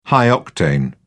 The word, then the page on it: high-octane: British English